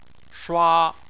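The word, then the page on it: shua: tone 1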